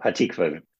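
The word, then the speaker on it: Hatikvah: Earlier in set one, during Evergreen, which is just a really sweet kind of nice tune, but you'll have to help me with the pronunciation, Catholic turned Lutheran over here, but in about 5:35 into Evergreen, is it Hatikva? – Yep, Hatikva.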